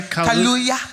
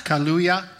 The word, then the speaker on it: Kaluuya: Kaluuya. – Kaluuya.